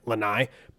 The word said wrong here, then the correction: Lahai: by Sampha. – Lahai. – Lahai. Oh, I had it as an N.